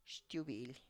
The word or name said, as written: xtubily